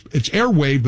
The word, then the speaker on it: Airwaav: Airwave is spelled, it's Airwave, but it's spelled A-I-R-W-A-A-V. So remember that W-A-A-V spelling.